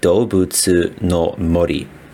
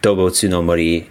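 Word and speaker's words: Doubutsu no Mori (どうぶつの森): And in Japanese, how would you pronounce that? – In Japanese, that would be Doubutsu no Mori. And that's the title that all the games carry in Japan. Doubutsu no Mori, and then the specific title of each game.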